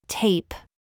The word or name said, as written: PIPE